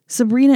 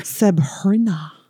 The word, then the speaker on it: Sebhrenah: Sabrina is spelled S-E-B-H-R-E-N-A-H. – Really sounding it out there. – Sabrina.